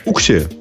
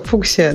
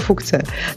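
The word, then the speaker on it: Fuchsia: Да, окей, хорошо, фуксия. Да, действительно, очень даже похоже. Но я думаю, что на английском это произносится не как фукция.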